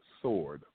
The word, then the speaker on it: sword: I'm sorry, the W is silent, sword.